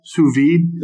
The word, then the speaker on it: sous vide: And next week, we're going to come back a little bit more full force and actually get deeper into the how do we say it, guys? Sous vide.